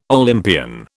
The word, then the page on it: Olympian: British English